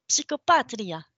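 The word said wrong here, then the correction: psicopatica: Non, c'est psychopatica.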